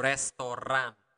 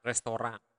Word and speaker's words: restoran: But the difference is that in Bahasa Bali or Bahasa Indonesia, we really pronounce the R. So restoran, restoran.